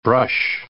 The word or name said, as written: brush